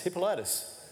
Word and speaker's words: Hippolytus: It's Hippo, then Lytus. Hippolytus.